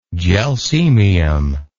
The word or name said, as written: gelsemium